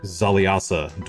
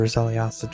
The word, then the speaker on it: Xaliasa: Foundation stones from each would know where the traitor Zaliasa dwelt, and where perhaps he hid his key to Runeforge. And Zaliasa is spelled X-A-L-I-A-S-A.